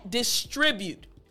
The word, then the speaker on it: distribute: So let me explain to you how to actually pronounce this word. Again, the word is a little bit longer. The word again is distribute. All right, distribute. Now, what I want to focus on is the tr, distribute, distribute.